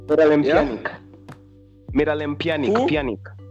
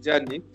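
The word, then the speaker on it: Pjanić: Miralem Pjanic. – Okay. Yes, I hear you. Actually, me, I always pronounce it as Pjanic. I don't pronounce the P.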